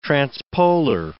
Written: transpolar

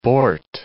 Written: bort

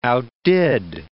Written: outdid

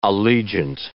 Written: allegiant

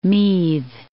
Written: Meath

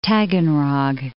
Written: Taganrog

